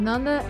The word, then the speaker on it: Nanda: You can find me on Twitter at Nanda, that's NANDAPRATES, Nanda Prates _.